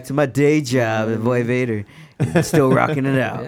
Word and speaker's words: Void Vator: It's all just Void Vader. – Mm-hmm. Void Vader, but V-O-I-D, the most important part.